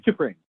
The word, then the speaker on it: T'Pring: Am I saying that wrong? Maybe it's not T'Pring. – Like the guy from Shawshank Redemption, which I think was Dufresne. – That was Dufresne. No, it is T'Pring.